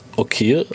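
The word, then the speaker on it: Okir (אוקיר): Aleph Vav Kof Yod Reish, pronounced Okir, I will cherish.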